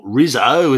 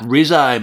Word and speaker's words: Rizzo: Thank you for your pronunciation, your Italian pronunciation of Rizzo. Bravo. Not Rizzo, as we'd say in Australia. Mark Rizzo, mate.